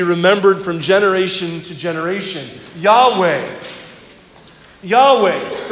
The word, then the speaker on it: Yahweh: Four simple Hebrew letters, Yud, Hey, Vav, Hey, probably pronounced Yahweh.